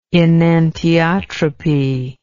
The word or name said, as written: enantiotropic